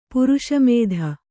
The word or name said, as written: ಪ್ರಶಮಿತ